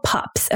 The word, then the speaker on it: PUPPS: All right, moving on to something called pups, not like woof, woof, not like that kind of pups, but it's called PUPPS, and it stands for pruritic urticarial papules and plaques of pregnancy. That is a mouthful of P's. I probably pronounced one of those wrong, but it's called pups, okay?